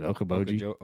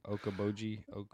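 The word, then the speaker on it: Okoboji: I can't pronounce it. Okaboji? Okeechobee? – Yeah. – No. Okeechobee of the North? Is that what it's called? – Okeboji.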